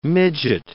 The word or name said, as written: midget